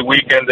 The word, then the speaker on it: weekend: No, non ho raccolto qualche sfida perché sono via per il weekend e non è cosa. – Vabbè, a me è piaciuto molto che ne hai messo l'accento sulla I di weekend.